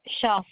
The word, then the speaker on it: Schaaff: Yeah, S-A-N-D-Y. – And what is your last name? – Schaff, S-C-H-A-A-F-F. – Okay. Is that S-C-H-A-A-S-S? – F-F.